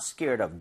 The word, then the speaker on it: scared of: So she's not scared of dogs. – Yeah. – Very nice. – Mm-hmm. – 근데 이게 빠르게 읽잖아요. – 네. – 그럼 이제 연음 현상이 벌어지면서요. 여기가 리을 비슷하게 발음이 될 거예요.